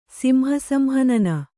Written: ಸಿಂಹಸಂಹನನ

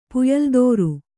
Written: ಪುಯಲ್ದೋರು

